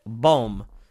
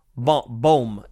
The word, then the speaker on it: Bohm: Because when you look at an Alec Bohm, and I always say his name wrong, let me make sure. Bohm.